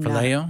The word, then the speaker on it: phileo: Am I pronouncing that right? Y'all, I'm phileo? – Not. Phileo love, yes.